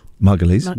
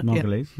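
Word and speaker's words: Margolyes: The niece of Miriam Margoyles. – Margulies. – Margulies.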